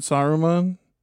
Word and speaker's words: Saruman: Sauron and Saruman? – Yes, you got it, man.